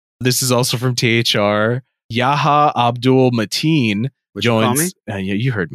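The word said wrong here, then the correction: Yahya: You don't do Big Daddy Yaya that way, bro. It's Yaya.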